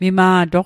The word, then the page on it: mima'toq: Mi'gmaq